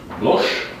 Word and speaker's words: Bloch: Se notează cu Y, R-A-Y-M-O-N-G. Bloch se pronunță, dar se scrie cu H. Deci B-L-O-C-H. Nu se pronunță Bloch. Poate am fi tentat dacă am ști un pic de franceză, ci Bloch, ca și cum va scrie cu C și K.